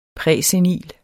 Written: præsenil

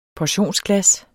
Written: PORTIONSGLAS

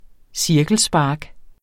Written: cirkelspark

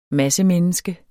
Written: massemenneske

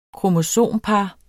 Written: kromosompar

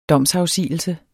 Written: domsafsigelse

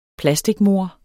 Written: plasticmor

